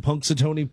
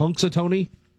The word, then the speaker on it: Punxsutawney: Punxsutawney, Pennsylvania. – Yep. – Did I pronounce it right? – Yep. – Punxsutawney.